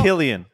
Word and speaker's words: Cillian: It's Killian.